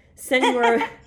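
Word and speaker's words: Señor: Once they arrive, they meet Senor, Senior, Senior, a Senor. – now I don't know how to say the word. Say it for me. – Senor. – Senor Bob, thank you.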